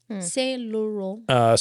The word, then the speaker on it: Saint Laurent: So please correct me if I pronounce any of these wrong. Saint Laurent. – Saint Laurent.